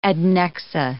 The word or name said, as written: adnexa